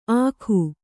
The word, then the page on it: ಆಖು: Kannada